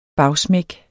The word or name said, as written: bagsmæk